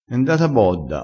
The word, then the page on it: e ndata bodda: dialettale